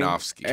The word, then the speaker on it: Aronofsky: Aronofsky.